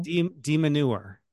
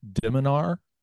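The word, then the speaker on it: de Minaur: DeMeneur. – Is so that not? I think because it's technically pronounced Diminar, I believe, or at least that's closer to the correct pronunciation, Alex Diminar.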